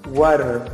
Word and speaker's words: water: Entonces, breve tip que les doy o consejo, que ustedes ya quizá más adelante van a ir aprendiendo, pero la de, la T en inglés, cuando hablamos muy rápido y todo eso, se escucha o prácticamente tiene un sonido de dedo en español, ¿correcto? Entonces por eso se pronuncia water, porque en sí se escribe con T, pero se pronuncia como si fuera una D, ¿ok?